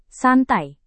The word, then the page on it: santai: indonesian